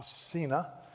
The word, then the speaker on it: Cena: Is it Cena? Cena? Is it Cena or Cena? Cena. Thank you, Cena.